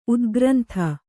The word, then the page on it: ಉತ್ಕ್ರಾಂತಿ: utkrānti